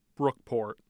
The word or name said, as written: brookport